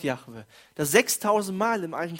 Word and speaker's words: Jahwe: Das ist nicht ein Wort, also ein Tetragramm, das kann man eigentlich nicht aussprechen, wenn man es liest, wenn man Hebräisch äh, gelernt hat. Ich habe es gelernt, Jahwe kann man nicht aussprechen. Wir sagen Jahwe.